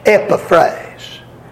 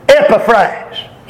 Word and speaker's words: Epaphras: But now, from what I'm told, now I didn't make this up, but what I'm told, the accent ought to be on the very first syllable, and it ought to be pronounced Epaphrase. Epaphrase. How many of you see that name? Huh? All right. Even as ye also learned of Epaphras. You see that short E and the short A and another short A?